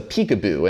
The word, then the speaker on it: PCAOB: It's called the PCAOB, but apparently it's pronounced the peekaboo.